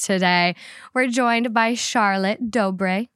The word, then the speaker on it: Dobre: Dobre.